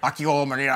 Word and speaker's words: Akio Morita: I say, like, Akio Morita, and then he's like, Akio Morita.